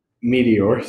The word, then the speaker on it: meteor: Like, you can't go around wrestling fucking alligators that have been alive since the fucking meat eater. How do you say that? Meteor. Sorry, my bad.